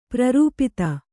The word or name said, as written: ಪ್ರರೂಪಿತ